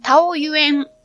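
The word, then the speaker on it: 桃園: この日は、お昼発の高速鉄道で台北へ向かって、台湾、桃の園と書いて、タオユエン国際空港から名古屋へ帰るだけです。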